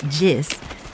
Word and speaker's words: Jess: Floris Marjolaine and Jess, pronounced Jis, catch me, and we navigate together, flat but surprisingly hard to walk on terrain.